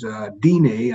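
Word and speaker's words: Diné: which is Dine, I believe they pronounce it, D-I-N-E.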